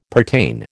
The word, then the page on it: pertain: American English